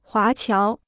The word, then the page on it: 华侨: huá qiáo